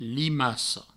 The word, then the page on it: limaca: provençal; limaco